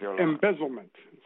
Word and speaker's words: embezzlement: Esto, yo creo que el idioma inglés tiene una palabra muy bonita para eso, embellishment, ¿ok? – Embezzlement. Sí.